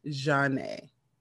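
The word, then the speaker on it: Zhané: So the name is spelled Zhane. So, of course, they had to put on the album, it's pronounced Jeanne. So, yes, this is by the group Jeanne.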